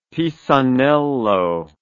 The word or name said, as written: Pisanello